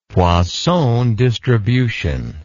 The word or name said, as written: Poisson distribution